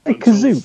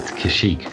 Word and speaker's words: Kashyyyk: Did he say Kazook? Further Yeah. Yeah, they do mispronunciate Kashyyyk a couple of times in this.